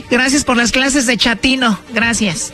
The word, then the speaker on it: chatino: Chapino. – ¿Chapino? – No, chatino. – Es como chato, ¿verdad? Chatino. – Sí, así me era como chato, chatino.